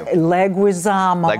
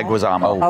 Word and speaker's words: Leguizamo: And then it is John Leguizamo. – Yes, thank you. – Leguizamo. – Leguizamo.